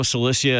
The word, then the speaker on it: Cilicia: So no Cilicia and Pamphylia nearby, or no. – Myra or Lycia? – No, you did a very good job pronouncing those, by the way.